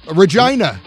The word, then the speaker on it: Regina: Regina. Rhymes with something else.